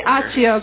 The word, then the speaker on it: Accio: But everyone says that sounds I say Accio because I'm Italian, and I see 2 C's, and I got to go chi.